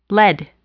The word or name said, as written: lead